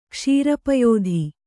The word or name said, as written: ಕ್ಷೀರಪಯೋಧಿ